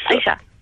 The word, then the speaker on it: Aisha: Yes, Aisha. – How do you spell that? – A-I-S-H-A.